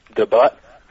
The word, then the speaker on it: debut: One, I would like to thank you for allowing me to make my internet radio debut. I know it's pronounced debut, but I just wanted to say but.